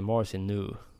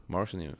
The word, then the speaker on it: Marcinu: har källor på Simor då som säger att det är Marcinu. – Marcinu, ja. – Inte new, utan nu. nu. Ja.